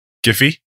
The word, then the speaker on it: Giphy: It's not Jiffy, because that's peanut butter. And Jiffy is also what you do in a hurry.